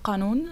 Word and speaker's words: Kanun: Das Wort Kanun wird Kanun ausgesprochen auf Arabisch.